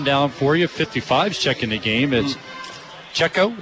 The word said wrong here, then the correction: Seiko: is that how you say it? Seiko. Right, Seiko, number 55 into the ballgame.